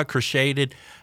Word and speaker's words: crocheted: Christina says my grandma crocheted. Is it in the past sense? – Crocheted. – Is it crocheted? – Yeah. – Because that's not how it's spelled.